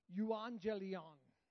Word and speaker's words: euangelion: It's the Greek word, and we're going to pull it up here, and I've got it phonetically sounded out here. It's euangelion. Euangelion. There it is.